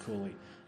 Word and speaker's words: Cowley: spelt Cowley, but pronounced Cooley, who was probably the most famous of the metaphysical poets of the mid 17th century.